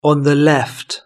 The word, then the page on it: on the left: British English